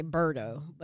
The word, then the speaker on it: Bordeaux: In today's podcast, we doing Bordeaux. Am I saying it correctly? – You're saying it correctly. – Because I wanted to say Bordeaux, but it's Bordeaux.